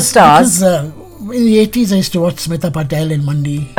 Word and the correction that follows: Patil: If only you got the name right. Not Patel, Patil.